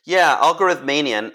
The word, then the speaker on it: algorithmania: Yeah, algorithmania. And first of all, congratulations on pronouncing it the way that I think about it, because a lot of people get tripped up with it.